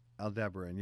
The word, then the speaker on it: Aldebaran: And it's pretty, you know how far I don't know how you pronounce it, Aldebaran is away, the one in Taurus? – Aldebaran, yes.